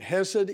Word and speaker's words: chesed: Many people pronounce it chesed. And there is a hate, which I forget which letter of the Hebrew alphabet, but very close to it is the hate. One is a hate and the other is a hate. And so chesed is spelled with the hate at the beginning, and the proper enunciation is hate, with the little guttural of the Hebrew language.